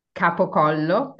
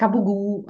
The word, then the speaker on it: capocollo: And then some cured sliced meat like capocollo, capocollo, that's how we pronounce it in Italian. I know that in the Italian American community, it's like capugu, but in Italian, not dialect. And it's capocollo or soppressata.